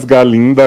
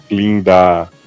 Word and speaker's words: Galinda: Não sou mais Galinda, agora o Gá é mudo, sou Linda.